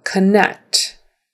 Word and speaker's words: connect: a come for the unstressed syllables. So connect, connect.